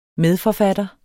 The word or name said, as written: medforfatter